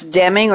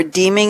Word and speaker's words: Deming: It's W. Edwards Deming, or Deeming if you pronounce it that way, D-E-M-I-N-G, and it's, "In God we trust, all others must bring data."